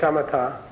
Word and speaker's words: shamatha: they call it samatha. Samatha. This is the correct pronunciation. So you're going to say shamatha.